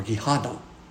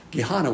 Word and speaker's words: Gehenna: Fiery is the word per, P-U-R, and hell is Gehenna, or Gehenna. That's how you pronounce it in Greek. Gehenna